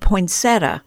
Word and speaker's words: poinsettia: And in my book, if you said poinsettia, you're also right on the money. We who plant our roots in the middle of the country tend to give the slouchy, more common pronunciation, all the while knowing that folks from down east or out west would probably not hesitate to correct us toward adding that extra I on the end.